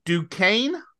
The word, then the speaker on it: Duquesne: Duquesne, I think is how you say it. – Yeah, that's how you say that.